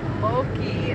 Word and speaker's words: Moqui: Moki, and it was spelled just like that sign. – M-O-Q-U-I. Moki.